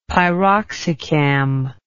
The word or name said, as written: piroxicam